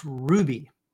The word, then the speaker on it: Rouxbe: And Karen says, how do you pronounce the R-O-U-X-B-E word? It's pronounced Ruby, as if it were spelled R-U-B-Y.